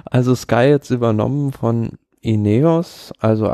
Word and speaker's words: Ineos: Ineos? – Mhm.